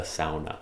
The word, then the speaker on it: sauna: Or as my family would pronounce that word, the sauna. – The sauna. – Because my family's Finnish.